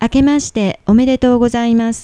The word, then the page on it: 明けましておめでとうございます: あけまして おめでとう ございます; akemashite omedetou gozaimasu